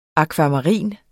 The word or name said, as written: akvamarin